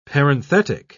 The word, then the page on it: parenthetic: British English